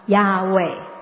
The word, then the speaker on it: Yahweh: 所以犹太人就把主在希伯来文是 Adonai，主这个字里面的母音加进去 YAHWEH，加了 A 跟 E，于是神的名就可以发音了，亚伟。我们和合本是把它翻成耶和华，但是希伯来文就是亚伟，所以有一些圣经版本就把它翻成亚伟，典雅的雅，畏惧的畏。